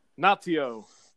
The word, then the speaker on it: Naito: Tetsuya Naito. – Yes, Naito. Yeah, I'm just putting Naito.